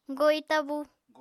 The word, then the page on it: goi tabú: Apatani